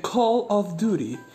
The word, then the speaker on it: Call of Duty: Bueno, entonces estaba diciendo que esperaba que en el siguiente, perdón, que lo dije mal, dije Carlos Duty casi, no. En el siguiente Call of Duty eh, Latinoamérica saliera en forma de DLC, ¿no?